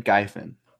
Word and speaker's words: Ghaifan: How do you say that? Ghaifan. – No idea. – I-F-A-N, Guyfan. – Guyfan. – Guyfan. – Johanna Guyfan.